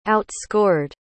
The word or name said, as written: outscored